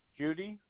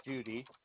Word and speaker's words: Judi: Judy Deatherage, Deatherage. – Okay. I'm sorry. Did you, will you spell that name again? Because I don't see it here. – J-U-D-I, Judy, and the last name is Deatherage. D as in David, E-A-T-H-R-A-G-E.